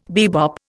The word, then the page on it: bebop: Indian